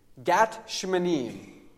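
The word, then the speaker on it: Gat Shmanim (גת שמנים): And he told us that Gethsemane, that word that we've used, we've anglicized it to use in our English language, Gethsemane is actually a bad mispronunciation of two Hebrew words, Gat and Shmanim. Gat Shmanim.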